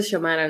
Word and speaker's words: Xiomara: Así se pronuncia Xiomara, ¿no? – Sí.